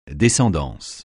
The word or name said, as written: descendance